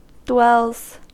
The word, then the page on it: dwells: American English